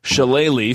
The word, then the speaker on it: shillelagh: I think I could practice spelling shillelagh for the next decade, and I wouldn't get it right. But am I at least pronouncing it correctly? – You're pronouncing it perfectly.